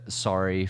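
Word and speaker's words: Sarri: Again, that sorry from how do we say it? – Sorry, S-A-R. Is it just sorry? – Yeah. – Oh, that's fantastic.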